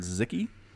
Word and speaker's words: Sziky: Man, it was an amazing game between White Raw and a Hungarian player named Zicky, which is spelled S-Z-I-K-Y. – All right. – Hungary is not known for StarCraft, right? – Not that I'm aware of. – But man, Zicky gives Protoss players such a hard time.